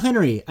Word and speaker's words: Hendrie: Oh, Phil Henry.